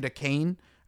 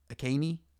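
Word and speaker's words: Achane: I mean, their backup running back, the kid Akane, I don't know, is that how you pronounce his name? Devon Akane? Akaney? I think it's Akane, because that's how they were pronouncing it.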